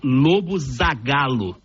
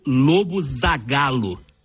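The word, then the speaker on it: Lobo Zagallo: Mario Jorge Lobo Zagalo. – Oye, muy bien. Mario Jorge Lobo Zagalo.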